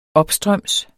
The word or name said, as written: opstrøms